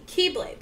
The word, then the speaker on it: X-blade: But the reason he did it is for what is called the Keyblade, but it's spelled X-Blade.